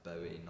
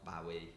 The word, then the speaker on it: Bowie: It's pronounced like Bowie, not Bowie. It's like a Bowie knife.